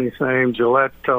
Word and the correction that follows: Gilotti: Or Gilotti, yeah. – Yeah, Gilotti.